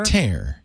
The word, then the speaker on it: tare: And it has, I'm not even sure how you pronounce T-A-R-E. Is it just tear? – Tear, yeah.